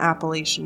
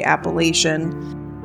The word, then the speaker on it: Appalachian: Appalachian Mountains. Or if you're from that area, I know a lot of people pronounce it Appalachia Mountains. I'm from the West Coast and we say Appalachian.